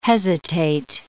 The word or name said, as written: hesitate